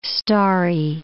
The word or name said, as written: starry